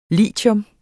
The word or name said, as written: LITIUM